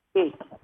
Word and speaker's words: Reiki: Tell me, is it pronounced Reiki or Reiki? – Reiki. – Actually, Reiki. I heard Reiki. – It was Reiki. – Reiki. – Oh, Reiki. Yeah, Reiki, right.